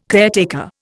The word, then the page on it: caretaker: Indian